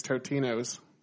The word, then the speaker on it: Totino's: Well, I'm so fat that I say it right. It's Totino's.